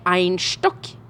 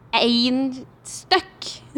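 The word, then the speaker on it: Einstök: Det heter ikke Einstock, men Einstøkk. Med trykk på ei, som betyr unik på islandsk. – Ja. – Einstøkk.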